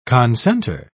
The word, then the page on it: concenter: British English